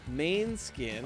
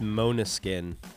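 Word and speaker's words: Måneskin: Laura Cox, Head Above Water, Main Skin. – Oh, yeah, that's actually pronounced Monoskin. – Oh, is it? – Because they have like the little tilde, or whatever they're called.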